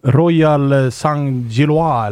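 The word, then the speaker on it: Royale Saint-Gilloise: Royal Saint-Gillois eller något sånt här. – Ja, men det var bra uttal.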